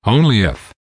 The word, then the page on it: only if: American English